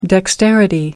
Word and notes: dexterity: American English

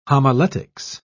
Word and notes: homiletics: British English

